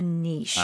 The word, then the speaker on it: niche: Now, there are two pronunciations. Mm-hmm. I've kind of taken, I like, it's French. – Yes. – This word is French. It's N-I-C-H-E. Mm-hmm. It can be pronounced niche, mm-hmm. which is the kind of UK. – Like, right, uh-huh. Yeah. But Americans say niche.